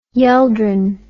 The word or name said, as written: yeldring